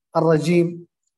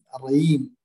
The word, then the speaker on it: الرجيم: الرجيم، نطق الجيم عند بعض يعني بعض يعني مثلا الرييم، وهذا إن كان قليل لكنه يعني أهل الكويت وأهل يعني أهل الكويت وأهل بعض أهل حضرموت عندهم الجيم.